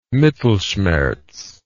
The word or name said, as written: mittelschmerz